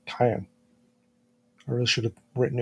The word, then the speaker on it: Cain: Now, the subtitles show the name was spelt Cain, as in the Bible character Cain and Abel, but they pronounce it Kyan.